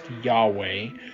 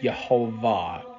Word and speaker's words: Jehovah: That's YHVH, or the Tetragrammaton, which it is not pronounced Yahweh, it is pronounced Jehovah.